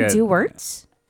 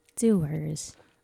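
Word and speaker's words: Dewar's: Is it Duwertz? Doers. – However you pronounce it, I'll pronounce it. – Okay. – It's like doer. It's like doer with, you don't pronounce the T and the S.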